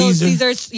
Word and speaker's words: Little Caesars: you've probably heard Little Caesars being mispronounced to Little Caesar.